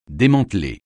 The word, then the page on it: démanteler: français